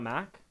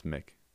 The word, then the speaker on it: McNeil: It's not Mac? – No, it's Mick.